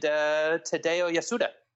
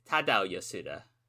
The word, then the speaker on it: Tadao Yasuda: Satoshi Kojima and Tadao Yasuda. – I think it's Tadao Yasuda.